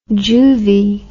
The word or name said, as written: juvie